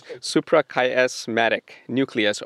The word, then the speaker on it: suprachiasmatic nucleus: It's suprachiasmatic nucleus, or SCN, of the brain.